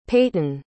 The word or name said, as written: paityn